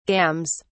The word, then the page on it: gams: British English